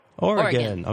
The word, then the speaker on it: Oregon: So how do you say it? Because we'll say it the way you say it. – Oregon. – Okay. Oregon it is.